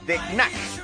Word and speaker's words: The Knack: The Knack. Con K al principio y luego N para fastidiar en la pronunciación.